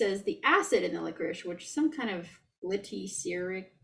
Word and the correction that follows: glyceric: Yeah, glyceric acid in licorice, when it dissolves, it conducts electricity, and she can use it to call Discovery.